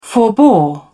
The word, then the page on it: forbore: British English